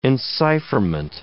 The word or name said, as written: encipherment